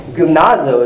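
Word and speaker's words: gymnazo (γυμνάζω): What's called gymnazo is how you pronounce that.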